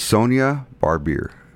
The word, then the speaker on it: Barbier: And her name is Sonia Barbier.